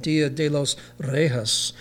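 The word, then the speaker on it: Día de los Reyes: In Spanish, it's pronounced something like Dia de los Reyes."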